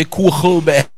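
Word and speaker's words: Kogelberg: The Afrikaans people would say Kochelberg.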